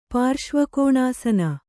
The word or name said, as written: ಪಾರ್ಶ್ವಕೋಣಾಸನ